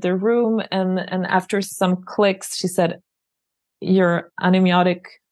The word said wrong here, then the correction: amniotic: and you have to correct me if I'm saying it wrong here. Anemiotic So your amniotic fluid, amniotic, which is the water inside the bag that baby's lying in. Yep. – Amniotic fluid, thank you, is very low.